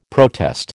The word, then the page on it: protest: American English